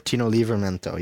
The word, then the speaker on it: Livramento: In terms of the transfer window, in midfielder Theo Walcott from Everton, defender Tino Livramento from Chelsea, is that how you pronounce it? – Yep, Tino Livramento, yeah.